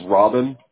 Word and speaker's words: Robbyne: It's Robin. It's spelled R-O-B-B-Y-N-E.